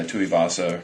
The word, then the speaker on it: Tuivasa: Two of Oz, is that it? Uh, Tuivasa. Tuivasa, yeah, it's I think Vasa.